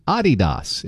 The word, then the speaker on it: Adidas: And Americans pronounce Adidas wrong. Adidas. It is supposed to be Adidas. It's a German company. The emphasis is on the first syllable, not Adidas, stressing the second.